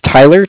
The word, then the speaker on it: Tyler: My name is Tyler, T-Y-L-E-R.